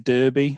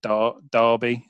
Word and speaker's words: Derby: It's a place called, well, you would pronounce it Derby, but we pronounce it Derby. D-E-R-B-Y. Derby. It's almost like with an A.